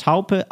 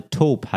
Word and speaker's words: Taupe: Wir waren stehen geblieben beim Stichwort Taupe, also Taub heißt das.